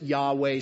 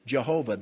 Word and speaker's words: Yahweh: Some pronounce it Yahweh, some pronounce it Jehovah.